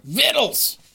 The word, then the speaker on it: victual: Vittles! – And that is correct! The word that looks like victual... – Yeah? – ...is pronounced vittle.